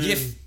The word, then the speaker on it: Yuffie: And that makes you think of in Final Fantasy VII when you would recruit Yuffie? Yuffie? Yeah. How do you pronounce it? Yuffie? – Oh, man. Yif.